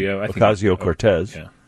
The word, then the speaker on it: Ocasio-Cortez: Ocasio-Cortez.